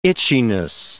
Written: itchiness

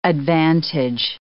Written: advantage

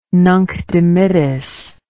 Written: Nunc Dimittis

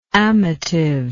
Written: amativeness

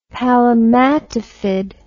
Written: palmatifid

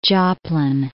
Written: Joplin

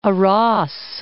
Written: Aras

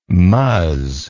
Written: muzz

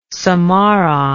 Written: samarang

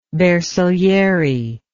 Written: bersagliere

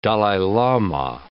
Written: Dalai Lama

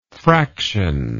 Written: fractionary

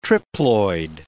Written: triploid

